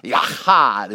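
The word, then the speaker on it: yahad (יחד): Now, when you speak in the Hebrew, you got to get the like of a good hacker in the back of your voice, because they go when they talk. It's yahad is kind of how it's said.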